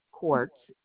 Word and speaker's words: Korcz: It's my name, Quartz, K-O-R-C-Z with a capital K.